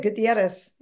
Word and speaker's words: Gutierrez: Gutierrez.